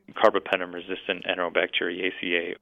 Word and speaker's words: Carbapenem-resistant enterobacteriaceae: Carbapenem-resistant enterobacteriaceae.